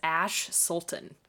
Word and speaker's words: Ash Soulton: Mine is Ash Soulton.